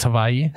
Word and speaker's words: Hawaii: The guy says it's actually pronounced Hawaii.